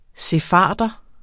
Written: sefarder

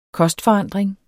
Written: kostforandring

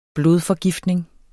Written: blodforgiftning

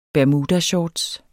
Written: bermudashorts

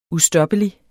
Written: ustoppelig

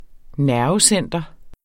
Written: nervecenter

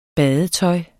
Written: badetøj

